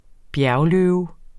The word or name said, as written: bjergløve